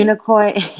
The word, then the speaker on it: Unicoi: Unicoi? Is that right? – Unicoi. – It's like, it sounds like unicorn. – It's similar to a unicorn.